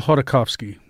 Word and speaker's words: Khodorkovsky: Mikhail Khodorkovsky. – Khodorkovsky, who was an oil magnate, an oil oligarch.